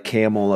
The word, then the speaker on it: Campbell: Sort of like Camel, Ohio, and it's spelled Campbell.